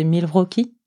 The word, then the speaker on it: Milwaukee: Alors je crois comprendre qu'en fait il faut prononcer Milwaukee, oui.